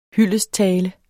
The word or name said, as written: hyldesttale